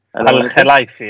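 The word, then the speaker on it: Al-Khelaifi: El Khelaifi? Al Khelaifi, ja.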